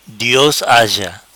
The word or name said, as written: Dios haja